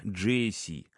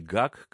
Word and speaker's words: GAC: Сразу оговорюсь, название бренда просят произносить именно так – GAC, а не ГАК, как принято у нас.